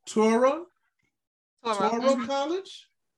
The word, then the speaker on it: Touro College: Receiving her undergraduate degree from Hampton University in Hampton, Virginia, she then matriculated to, I hope I say this right, Touro College? – Yes.